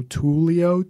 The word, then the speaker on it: Tulio: Oh yeah, my handle is my name spelled phonetically, so Tulio, TOOLEEOH.